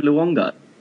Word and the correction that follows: Luongo: Yeah, Massimo Luongo. – Jeez, can you pronounce anything? – I'm hopeless. I'm hopeless. Luongo. Luongo.